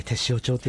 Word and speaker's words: 天塩町: そう、はい、天のお塩と書いて天塩町って読んだ、はい、北海道でも結構遠いんだよ、はい、ここから来たのは。